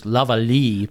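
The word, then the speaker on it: Morel-Lavallée: I think the French is Lavallee, but we call them Morel Lavallee lesions.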